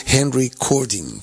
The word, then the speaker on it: Henry Cording: Henry Cording, si se fijan un poco como suena, recording, es decir, la palabra Henry, pero al estar, digamos, con Y, pues coge la pronunciación inglesa, ¿no? Y como era, digamos, una estrella del rock and roll, pues tenía un nombre anglosajón. Y Cording, que sería el apellido Cording, si lo juntas en recording, ¿no?